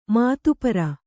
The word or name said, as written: ಮಧುಪೂರ